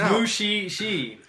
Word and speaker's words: Mushishi: It's Mushishi. M-U-S-H-I and then S-H-I.